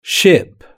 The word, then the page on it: ship: British English